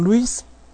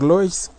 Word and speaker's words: Lois: Krypto escucha algo y es herido por Luis, o por Lois, como les guste pronunciarlo, quien ahora también es una Black Lantern.